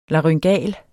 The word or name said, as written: laryngal